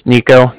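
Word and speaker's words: Niko: Again, my name is Nico. That's N-I-K-O.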